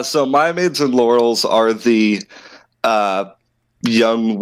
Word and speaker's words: Mia Maids: Key changes include what's reported and the opportunity to invite Laurels and Mia maids to serve as Maya maids. – Maya maids, okay.